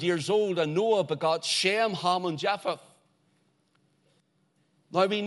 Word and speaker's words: Shem: For example, the name Shem, S-H-E-M, and it's pronounced in the Hebrew, Shem. That's the way it goes. Almost the same, but like our shame, S-H-A-M-E.